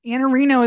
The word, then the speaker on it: Iannarino: Anna Reno is how you pronounce it, but your Twitter is IANNARINO, LinkedIn.